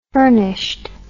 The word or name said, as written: furnished